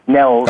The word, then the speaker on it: Nels: I mean, he said to me, he said, "Nels," and that's how you pronounce my name, by the way, Nels.